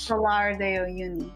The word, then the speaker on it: Salar de Uyuni: Salar de Uyuni.